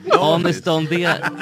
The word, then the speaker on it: Anis Don Demina: Och Danis Don Demina. Var det rätt uttalat? Ja, Don Demina. Demina, herregud. Alltså, för lyssnarnas skull så är... Danis Don Demina. Anis Don Demina.